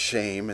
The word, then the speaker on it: Shem: As a reminder, that's S-H-E-M. We would pronounce it Shem, but it's pronounced Shame in the Hebrew.